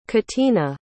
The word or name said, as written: catina